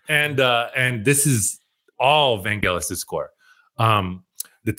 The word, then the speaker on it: Vangelis: This is a hard G, okay? Vangelis. Okay. All right. – Okay. – This does not rhyme with Los Angeles, okay? – Okay.